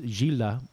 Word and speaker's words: Gilda: One of the showings was for a movie about Gilda, pronounced Gilda by Argentinians.